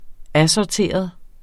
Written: ASSORTERET

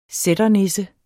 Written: sætternisse

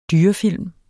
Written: dyrefilm

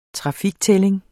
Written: trafiktælling

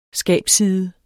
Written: skabsside

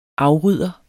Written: afrydder

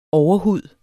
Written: overhud

